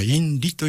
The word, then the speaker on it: INRI: でそれの 4 文字の頭文字を取って INRI と書くと、まあ、インリというふうに発音したりするんですけども、